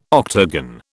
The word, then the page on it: octagon: British English